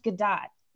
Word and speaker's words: Gadot: I also wanted to side note, apparently her name is pronounced Gadot. So if anyone thinks I'm saying it wrong, you're wrong. She's not French, she's Israeli.